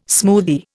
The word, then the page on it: smoothie: Indian